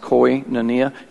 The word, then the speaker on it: koinonia: That's called, it's pronounced koinonia. You can Google it if, if you struggle with that.